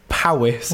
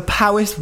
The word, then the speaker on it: Powys: Powys. It's spelled P-O-W-Y-S.